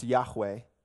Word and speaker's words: Yahweh: And that name in Hebrew is pronounced Yahweh.